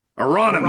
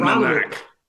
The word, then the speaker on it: Aronimink: Aronimink. – Aronimink. – Say it again. – Aronimink.